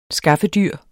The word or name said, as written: skaffedyr